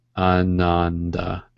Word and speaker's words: Ananda: A-N-A-N-D-A. Ananda.